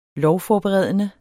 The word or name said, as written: lovforberedende